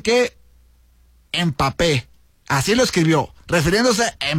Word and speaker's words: Mbappé: Y es que ya se lo pegó Miches, justamente aquí nos comenta Miches que le llama el papel, no, Mbappé, es francés, hay que recordar que ahí se pronuncia así.